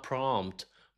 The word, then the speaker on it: prompt: تاني كلمه والناس كتير بيغلطوا في نطقها هي كلمه prompt. prompt في بيقولوا prompt prompt حاجات غريبه مش عارف ليه، لكن اسمها prompt prompt prompt ال p silent.